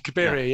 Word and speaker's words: Cabiria: and Calibri. I always get the pronunciation of that one wrong. – Cabiria.